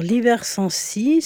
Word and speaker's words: Libersensi: Peux-tu nous présenter donc l'association Libère Sensi Alors Libère Sensi, c'est une association. D'abord Libère ça s'écrit comme ça se prononce L-I-B-E-R-S-E-N-S-I. E e donc Libère comme le début de liberté et Sensi comme le début de sensibilisation.